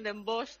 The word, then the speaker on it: Den Bosch: Le llaman Den Bosch también.